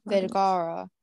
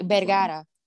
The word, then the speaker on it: Vergara: How do you say her name? Is it Sophia Vergara? – Vergara. – I don't want to say it like. – It's like Vergara.